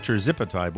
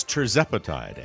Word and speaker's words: tirzepatide: I have been pronouncing it as tirzepatide, when in fact, a listener with ADD was nice to admit they have ADD and informed me that it's tirzepatide.